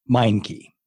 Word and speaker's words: Meincke: Meincke.